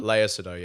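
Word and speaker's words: Léa Seydoux: Is it Lea Seydoux? – Lea Seydoux, yeah.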